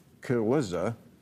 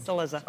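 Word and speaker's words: Cillizza: Here's Chris Kaluza on CNN talking about... I'm sorry, did I say it wrong? – Kaluza.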